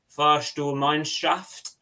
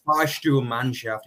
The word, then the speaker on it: Fahrstuhlmannschaft: Yeah, but I think, I'd give you an 8 out of 10 for your pronunciation on that one. yeah. Fahrstuhlmannschaft, yeah.